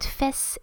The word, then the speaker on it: fesse: So sometimes the words la face, F-A-C-E, is often pronounced by students face, a bit like in English, but fesse in French, which is spelled F-E-S-S-E, fesse is the bum.